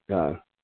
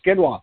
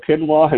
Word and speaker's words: quinoa: And see, number 15 here, try Kamut, K-A-M-U-T. That's another new one for me. When ancient grains trending, this one will battle quinoa. There we go. I see it in print. Can never say it right. With ancient grains, my wife shouts out in the background. No, it's quinoa.